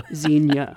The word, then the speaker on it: Xenia: X-E-N-I-A. Xenia, yes. – And it's two syllables, not three.